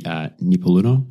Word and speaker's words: Nipaluna: So you're a proud Aboriginal woman from the Nipaluna lands, mm-hmm. Is that correctly pronounced? – Yeah.